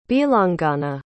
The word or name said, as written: bhilangana